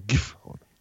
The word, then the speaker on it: Gifhorn: Mit der Silbe mit der Betonung auf der ersten Silbe. – Ersten Silbe, genau. Wie als wenn da 2 Fs wären. Gifhorn.